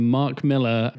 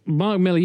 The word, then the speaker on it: Millar: Why do people say Mark Millar all of a sudden? – Americans do, because they're fucking idiots. – Do they see the A and go, well, it must be Millar? – Yeah, it can't possibly be pronounced the same way as Millar. – He's Scottish, it's Millar.